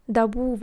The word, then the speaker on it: Darboux: Ondan sonra Dabu var. O da Darboux diye yazılıyor falan. Şu